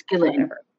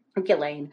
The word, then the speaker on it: Ghislaine: I always say her name wrong. – It's Ghislaine. – Ghislaine.